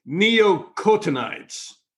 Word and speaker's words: neonicotinoids: And the very first thing I asked you off camera was how to say this word, neonicotinoids.